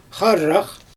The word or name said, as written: xarax